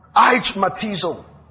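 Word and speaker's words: aichmalotizo: It's the word aichmatizo. I'll spell it for you. A-I-C-H-M-A-L-O-T-I-Z-O. A-I-C-H-M-A-L-O-T-I-Z-O.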